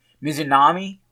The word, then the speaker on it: Mizunami: Mizunami, I think is how it's pronounced. – That sounds about right to me.